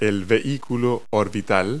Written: orbiter

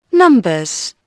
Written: numbers

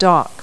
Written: dock

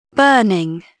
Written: burning

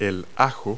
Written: garlic

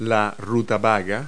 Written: rutabaga

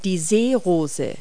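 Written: water lily

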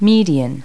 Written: median

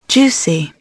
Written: juicy

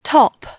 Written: top

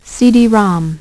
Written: CD-ROM

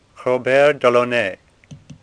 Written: Delaunay

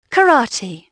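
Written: karate

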